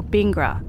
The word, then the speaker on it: Bingara: If you drive 7 hours northwest of Sydney, you'll come to Bingara, or Bingara, as the locals say.